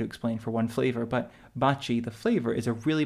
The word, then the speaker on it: Baci: B-A-C-I. It looks like Bacchi, I don't know how you pronounce it in English, but it's pronounced Baci in Italian, mm-hmm.